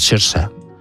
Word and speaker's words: Saoirse: que como es gaélico es Saoirse. Es como se pronuncia.